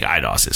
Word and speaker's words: Eidos: Sometimes I look at it, I'm like it's Eidos, but I think it's Eidos. I think it's Eidos. Eidos, sure. I think Eidos is correct. Montreal.